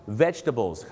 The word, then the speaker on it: vegetables: vegetables ครับผม vegetables เขาจะไม่ออกเสียงตัว jet ครับดังนั้นนะครับอย่าลืมครับ vegetables 3 พยาง